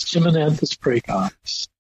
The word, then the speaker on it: Chimonanthus praecox: Chimonanthus praecox.